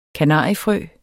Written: kanariefrø

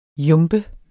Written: jumbe

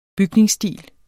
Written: BYGNINGSSTIL

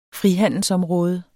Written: frihandelsområde

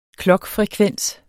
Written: klokfrekvens

